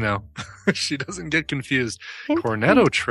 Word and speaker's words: Cornetto: Cornetto, is that how you say it? – Yeah.